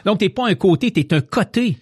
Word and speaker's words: Côté: C'est O-T-E. Mais pourquoi ça se... Bon, on prononce quand même côté.